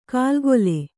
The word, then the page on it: ಕಾಲ್ಗೊಲೆ: kālgole